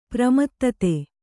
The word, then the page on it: ಪ್ರಮೋದೂತ: pramōdūta; Kannada